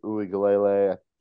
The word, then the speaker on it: Uiagalelei: And the latest one actually is DJ Uiagalelei. How do you say his name? – Uiagalelei.